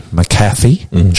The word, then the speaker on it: McAfee: McAfee.